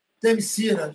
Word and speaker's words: Themyscira: Themyscira, Themyscira inspira, Achei muito legal toda essa, essa mitologia em volta disso, assim.